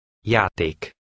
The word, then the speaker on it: Játék: Look at number 1, which has the Hungarian letter J. This is pronounced like the beginning of the English word yes. Try to read number 1. – Jól. – Read number 2. – Játék.